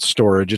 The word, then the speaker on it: Storj: It looks like StoreJ, S-T-O-R-J. They pronounce it Storage.